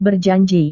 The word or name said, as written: berjanji